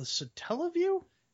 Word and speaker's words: Satellaview: There was also, and this is the coolest accessory I think, Japan only, the satellite view. Do you know about this one? – I believe it's called the Satella View. – Sorry, that's how you pronounce it.